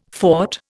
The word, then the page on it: fought: Indian